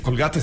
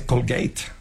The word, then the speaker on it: Colgate: Entonces, bueno, no se dice Colgate, se dice Colgate.